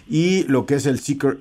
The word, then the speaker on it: Zeekr: Seeker C01 mire, si usted entra a internet y busca Seeker, por cierto, Seeker, si usted lo ve en algún vehículo, lo pronunciamos así, me parece que en la conferencia también se pronunció como Seeker, empieza con Z, lleva doble E, lleva una K y una R, Seeker, el, el 001, eh, pues un vehículo plenamente, plenamente deportivo.